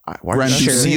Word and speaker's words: Granduciel: So his name is Adam Granduciel. Is that how you pronounce it? Granduciel. – Sure. Yeah.